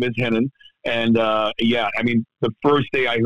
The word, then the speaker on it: Renan: And when you see it in America, it looks like Renan. But in Portuguese and Brazil, the R's are pronounced with an H.